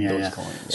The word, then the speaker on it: Dogecoin: Yeah, I have some Ethereum myself, and I have, well, I've heard it pronounced a couple of ways, like Doggycoin or Dogecoin. – Dogecoin.